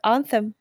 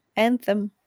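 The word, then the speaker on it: Anthem: Вот это настолько, как в демо, э, э как он правильно читается, антем, антем.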